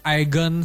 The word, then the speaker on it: Aegon: Film ini juga ngangkat kisah penaklukan Westeros oleh Agent One Targaryen ya, semoga gue pronounce-nya bener ya. Aegon.